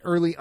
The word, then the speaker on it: Earley: I am at Actorak, which is spelled A-K-T-I-R-A-K. – And you can find me on Twitter at Early underscore Matt, and Early is spelled E-A-R-L-E-Y, slightly different than the traditional spelling.